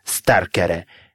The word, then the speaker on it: starkare: «Старк» плюс «аре» получается «старкаре». Обратите внимание, что ударение – из начальной формы прилагательного никуда не сдвигается.